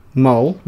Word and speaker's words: Mull: Mull. – Mull. – How do you spell it? – M-U-L-L. – Mull. Like mulled wine.